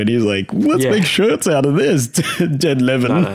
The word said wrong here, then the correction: Levine: Yeah, no, Ted Levine.